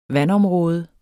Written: vandområde

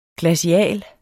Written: glacial

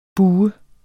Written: bue

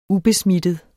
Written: ubesmittet

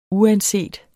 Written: uanset

